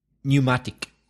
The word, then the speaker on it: pneumatic: Well, as an adjective, pneumatic. As a noun, it would be tire. But as an adjective, pneumatic. – So another silent P. P-N-E-U-M-A-T-I-C.